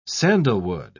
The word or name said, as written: sandalwood